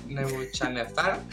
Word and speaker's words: Nebuchadnezzar: El siguiente juego, si puedo pronunciarlo, se llama Nebuchadnezzar.